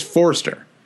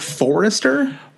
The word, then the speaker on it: Forster: Thinking, I still feel like you're adding a syllable in Fraser Forster's name, though. It's just Forster, Forster.